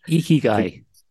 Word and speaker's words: ikigai: I don't know if I'm even pronouncing it right, but ikigai. – It's spelled I-K-I-G-A-I.